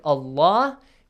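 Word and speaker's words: الله: make sure the pronunciation of the word allah because there is a fatha sound before it has to be heavily pronounced the lam Yeah.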